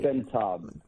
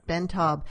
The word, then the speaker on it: Ben Taub: Ben Taub. – Ben Taub.